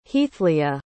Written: heathleah

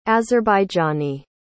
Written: azerbaijani

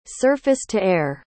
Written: surface-to-air

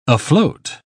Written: afloat

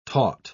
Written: taut

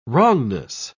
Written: wrongness

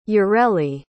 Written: yareli

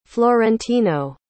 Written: florentino

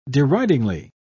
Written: deridingly

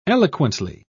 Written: eloquently

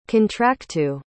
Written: contractu

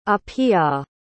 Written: apia

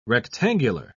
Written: rectangular

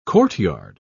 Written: courtyard

